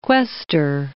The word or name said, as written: questor